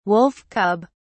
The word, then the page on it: wolf cub: British English